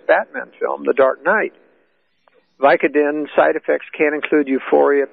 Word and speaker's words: Vicodin: And yet there's another story saying that after the massacre, Holmes calmly told detectives he'd taken 100 milligrams of the prescription painkiller Vicodin. Is that how you pronounce it? – Vicodin.